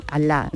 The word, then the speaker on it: Allah: pronunciado con imel andalusí, es decir, la con, como una I, ¿no? Allah, ¿no?